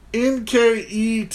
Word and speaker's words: Nketiah: His name is Edward Nketiah. – Oh, the H is silent.